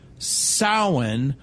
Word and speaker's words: Samhain: Now, it's pronounced sow like cow, but it's spelled S-A-M-H-A-I-N.